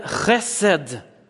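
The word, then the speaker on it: chesed (חסד): Där heter det chesed. Man ska harkla sig rejält så det kommer loss här nere.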